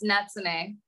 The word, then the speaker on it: Natsune: Don't worry, it's Natsune.